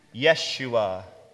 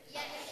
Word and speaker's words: Yeshua: In the Hebrew, or Jewish language, Jesus would be pronounced Yeshua. Can you say that name? – Yeshua. – Very good. Can you all say that name? – Yeshua.